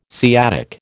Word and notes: sciatic: American English

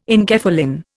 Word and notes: enkephalin: Indian

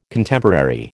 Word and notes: Contemporary: American English

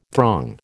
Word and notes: throng: American English

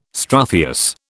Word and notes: struthious: British English

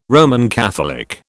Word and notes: Roman Catholic: British English

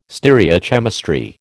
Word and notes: stereochemistry: American English

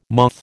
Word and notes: moth: American English

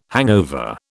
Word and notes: hangover: British English

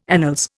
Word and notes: annals: Indian

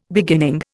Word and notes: beginning: Indian

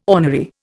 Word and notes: ornery: Indian

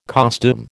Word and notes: costume: American English

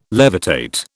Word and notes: levitate: British English

